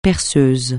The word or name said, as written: perceuse